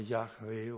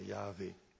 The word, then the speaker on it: Jahwe: Die Gelehrten sind sich nicht einig, aber die meisten scheinen sich doch einigermaßen geeinigt zu haben über diese Aussprache Jahwe oder Jahwe.